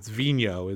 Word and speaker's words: Vigneault: I know. It's Vigneault. It's Vigneault is the correct pronunciation.